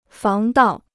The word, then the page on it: 防盗: fáng dào